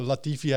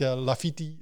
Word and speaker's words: Latifi: Oh, al, die, al die coureurs hebben Latifi, heet Lafiti en dat soort dingen.